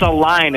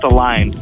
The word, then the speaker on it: Aline: Dude, it's Aline. It's spelled way like it's weird, but it's pronounced Aline.